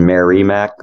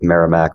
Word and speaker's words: Merrimack: You actually pronounce it right. Everyone else is like, it says Merrimack, like Merry Christmas, and you hit it right on with Merrimack.